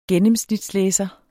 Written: gennemsnitslæser

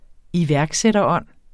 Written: iværksætterånd

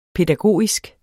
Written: pædagogisk

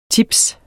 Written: tips

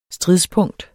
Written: STRIDSPUNKTET

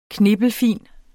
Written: knippelfin

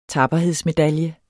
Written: VIDNET

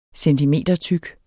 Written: centimetertyk

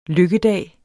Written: lykkedag

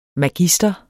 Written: MAGISTEREN

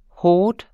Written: hort.

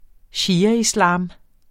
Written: shiaislam

